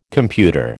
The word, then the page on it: computer: American English